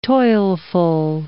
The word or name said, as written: toilful